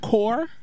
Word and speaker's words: corps: Yeah, core kills me. C-O-R-P-S kills me. Just C-O-R-E maybe.